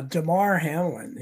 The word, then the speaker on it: Damar Hamlin: Now, Damar Hamlin is become like a George Floyd thing.